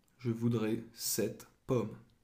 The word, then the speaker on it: sept: Sept. – And there's a silent P in there, so it's spelled S-E-P-T.